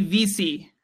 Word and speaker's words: Vesey: Tell it to the Sabres and Jimmy Vesey. I'm told that's how his name is pronounced.